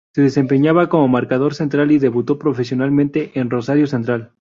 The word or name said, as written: profesionalmente